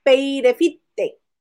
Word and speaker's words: Peirefite: Marianela Peirefite, que nos dijo. – es como dice Maganda. Pero nos dijo, se, se pronuncia como se lee. – Peirefite. peirefite. – Ajá.